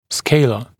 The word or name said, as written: scaler